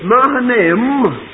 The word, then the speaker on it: Mahanaim: Very hard to pronounce. I had to go over it a lot of times to get it. It's Mahanaim, because I've got a self-pronouncing Bible and it does the work for me.